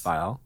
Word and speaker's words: Viall: Say it for us. – Viall. – This is for the record. For anyone who's mispronouncing it, now you have it on tape to say it properly.